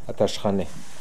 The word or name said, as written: Ateshkaini